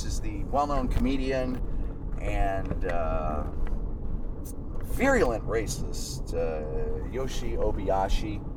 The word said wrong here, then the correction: Obayashi: It's actually pronounced Yoshi Obayashi. – Obayashi. – Like, oh my gashi.